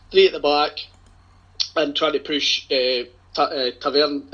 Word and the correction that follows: Tavernier: uh, sorry. – Well done. Let me pronounce that correctly. Tavernier and,